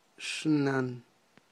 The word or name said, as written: xnan